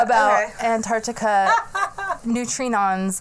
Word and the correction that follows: neutrinos: unlocked. – Neutrinos. – But, no, I keep saying it wrong.